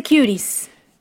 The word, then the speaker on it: Cuties: Coño, teacher, se dice Cuties.